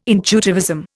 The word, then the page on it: intuitivism: Indian